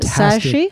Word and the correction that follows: Shahi: How do you yeah, say her name? Sashi, uh, Shahi. Sarah Sahi. Fantastic actress.